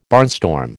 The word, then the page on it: barnstorm: American English